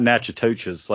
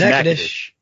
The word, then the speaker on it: Natchitoches: It's not Natchitoches like I want it to be. It's Natchitoches. Natchitoches, I got it. I had to say Natchitoches first before Natchitoches came out.